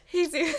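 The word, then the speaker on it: Jesús: This is when I learned that kids who have their names spelled Jesus are pronounced Jesus.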